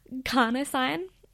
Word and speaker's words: carnosine: Well, beta-alanine, I'm going to go a little bit sciencey here, but basically it elevates your levels of this thing called carcinogen. Sorry, I completely botched that pronunciation. Carnosine, which basically what this means is that when you have more carnosine levels in your body, this helps your muscles reduce acid.